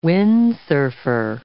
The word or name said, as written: Windsurfer